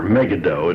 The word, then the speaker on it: Megiddo: And they came to fight in the valley of Megiddo, or Megiddo, it really should be pronounced.